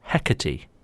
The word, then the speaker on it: Hecate: What is the Roman name for the goddess Hecate? – You pronounced it correctly.